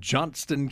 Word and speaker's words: Johnston: And Roy Lewis is a master gardener in Johnston County. I'll say that properly. Put the T in there.